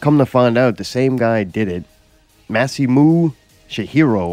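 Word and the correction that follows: Masamune Shirow: Masamune Shiro.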